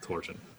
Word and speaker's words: torsion: Torsion. with an SH sound. I checked English language dictionaries in multiple countries, and not one of them has the ZH sound listed as an acceptable pronunciation. It's always just torsion, and that's it.